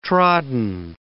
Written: trodden

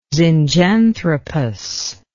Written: zinjanthropus

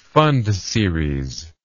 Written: pfund series

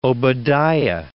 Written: Obadiah

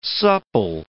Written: supple

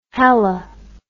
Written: hali

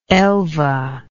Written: elva

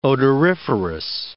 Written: odoriferous

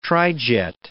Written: trijet

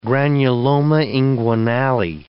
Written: granuloma inguinale

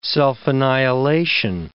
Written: self-annihilation

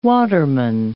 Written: waterman

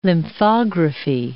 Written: lymphography

